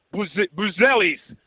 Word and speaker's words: Buzelis: Buzelis.